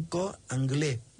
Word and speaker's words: cor anglais: Y como este era angulado, lo llaman cor anglais. La U casi no se pronuncia, no se pronuncia.